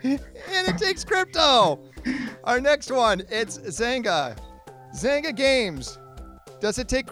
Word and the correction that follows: Zynga: Well, first of all, if you don't pronounce it right. – I'm oh, Zynga. Zynga. Excuse me, Zynga.